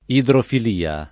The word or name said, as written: idrofilia